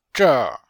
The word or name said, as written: zher4